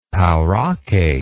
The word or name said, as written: pauraque